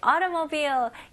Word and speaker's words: automobile: 네, automobile. 이 단어도 꽤 어렵죠? 오케이. 같이 한번 연습해 볼게요. 자, 여기서는 auto, T를 강하게 할 필요 없어요. 미국식 발음으로는 auto. auto 약간 가벼운 D 발음으로 생각하시면 좋아요.